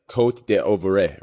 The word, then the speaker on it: Côte d'Ivoire: Ivory Coast is actually, the correct pronunciation is like, Côte d'Ivoire, right? So it starts with C.